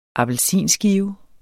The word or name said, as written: appelsinskive